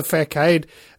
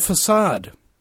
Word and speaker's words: facade: And then all of a sudden, the facade drops away. Oh, wait. I pronounced it wrong. It's called facade.